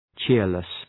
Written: cheerless

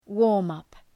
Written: warm-up

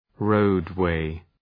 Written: roadway

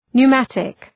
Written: pneumatic

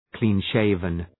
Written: clean-shaven

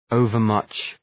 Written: overmuch

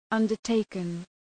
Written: undertaken

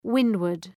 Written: windward

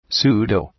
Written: pseudo

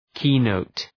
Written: keynote

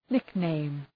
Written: nickname

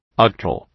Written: octal

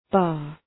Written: bar

